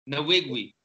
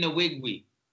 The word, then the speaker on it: Nwigwe: Nwigwe. – What's his name? – Toby Nwigwe.